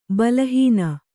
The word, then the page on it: ಬಲಹೀನೆ: bala hīne